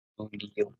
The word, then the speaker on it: Luglio: Luglio, Luglio.